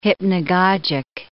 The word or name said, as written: hypnagogic